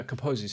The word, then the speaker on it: Kaposi: Help me out with pronouncing it Carpses sarcoma. – Yeah, Kaposi sarcoma. – Kaposi sarcoma. Thank Sorry.